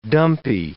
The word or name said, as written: dumpy